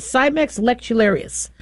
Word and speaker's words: Cimex lectularius: That's the genus and species, Cimex lectularius.